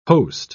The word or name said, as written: host